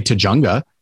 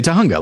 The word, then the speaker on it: Tujunga: But then no, it's like, we don't say Tujunga, we say Tahunga. Like, that's the correct pronunciation.